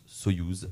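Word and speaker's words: Союз: Euh, voilà, c'est Soyuz, écrit du coup en biélorusse, mais ça se prononce Soyuz, voilà, avec le titre Glance.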